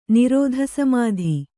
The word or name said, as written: ನಿರೋಧಸಮಾಧಿ